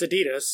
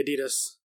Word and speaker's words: Adidas: Speaking of American-English differences, Dave Mateo asks, what is the correct pronunciation of Adidas? – You just said it. – It's Adidas. – It's Adidas, isn't it? – No, it's Adidas, yeah.